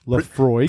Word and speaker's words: Laphroaig: And we had to look up the correct pronunciation, and it is Laphroaig.